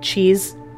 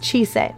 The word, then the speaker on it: Chise: I'm not gonna lie, when I first read it, I pronounced her name as like cheese. I wasn't reading it correctly. Don't judge me. But anyways, her name is Chise.